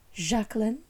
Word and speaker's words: Jacqueline: She's got the surname, and her name is always pronounced Jacqueline, like that's how she pronounced it.